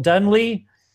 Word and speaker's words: Dunlea: And today we have Michael Dunley.